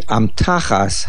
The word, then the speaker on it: amtachas: In Pasuk Beis, the Pasuk reads, Tosim befi amtachas hakotoin. Place in the youngest one's sack. Amtachas is Loshin Yochid, and the word is mil'el. The word is pronounced amtachas, the main accent on the tuf.